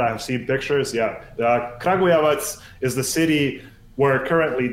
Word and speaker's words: Kragujevac: Do you know about the legendary Kragujevac? – Okay. – Kragujevac? – Kragujevac. – Oh, there you go. Yeah, Kragujevac.